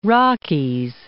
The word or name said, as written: Rockies